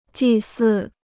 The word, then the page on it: 祭祀: jì sì